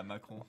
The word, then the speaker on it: Macron: And even President Macron, am I saying that right? – Yeah, Macron.